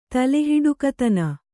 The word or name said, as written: ತಲೆಹೊಡಕತನ